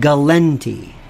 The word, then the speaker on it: Galante: Carmine Galante. They still pronounce it Galenti. It's the weirdest thing. It's why they all mispronounce his name, I have no idea.